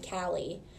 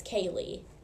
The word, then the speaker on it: Caleigh: So like C-A-L-E-I-G-H, everyone called me Callie. Well, it's Kaylee.